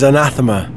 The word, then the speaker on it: Anathema: The official pronunciation is anathema.